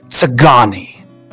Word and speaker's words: Tsagani: not Ska. No, let me pronounce that a little bit better. It's the... It gets really close to you, like uncomfortably close. – Oh. – It gets... It's the Tsagani.